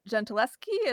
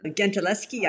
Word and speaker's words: Gentileschi: but Artemisia Gentileschi, is that how you say it? – Gentileschi, I think, yeah, I guess so.